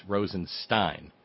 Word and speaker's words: Rosenstein: E before I is pronounced I before E is pronounced E, so it's Rosenstein. – It's I-E-N? – No, it's Bob? – It's S-T-E-I-N, Stein.